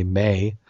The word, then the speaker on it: Maa: So, how you pronounce her name? M-A-A, May, 29, told police she had just found him and cut the suit off him.